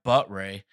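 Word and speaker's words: Buttrey: Then we look at, they just signed Ty Buttray. I had to look that one up phonetically because it doesn't look like that, but on a minor league deal, he seems like a super reclamation project because he was decent in 19.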